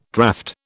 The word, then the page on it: draught: British English